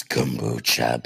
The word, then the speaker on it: kombucha: Kombucha? Kombat? How do you say it? I know you're probably screaming it. It's kombucha, bitch.